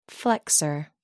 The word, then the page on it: flexor: American English